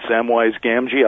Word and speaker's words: Samwise Gamgee: Ringo would have been his right-hand man, Samwise Gamgee.